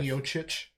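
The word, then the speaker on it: Miočić: Miocic.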